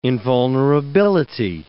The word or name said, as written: invulnerability